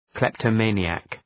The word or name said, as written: kleptomaniac